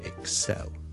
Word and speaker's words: excel: 个重音咧，其实咧佢系应该放喺 the second syllable 嘅，so excel。